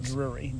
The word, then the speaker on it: Drury: Drury. D-R-U-R-Y. – That would have been my guess. – Like the Inn. Cool. If you're familiar with Drury Inn the lived on Drury Lane, the cookie guy.